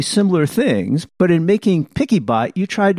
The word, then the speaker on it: PiccyBot: The only funny thing about it is, with most people who are blind using screen readers, many screen readers pronounce it as PixieBot. And when I was looking for it in the App Store, I was looking for PixyBot, but I couldn't find it. – And you spell it PICCYBOT, right? – Correct. But it is PickyBot, from picture and then, a picky and then a bot to help you with the pictures.